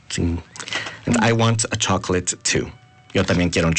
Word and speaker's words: chocolate: Igual, se escribe C-H-O-C-O-L-A-T-E. – ¡Oh! Pero se pronuncia de diferente manera, ¿verdad? – Ajá. Se pronuncia chocolate. Chocolate. – ¡Oh! Chocolate. I want a chocolate.